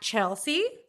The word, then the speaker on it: Chelsea: On Instagram, at Chelsea, C-H-E-L-S-E-A, the correct way to spell it, Frank, like Anne.